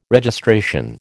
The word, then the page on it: Registration: American English